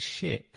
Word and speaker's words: Schiit: That is it. It is shit. But it's spelled SCHIIT.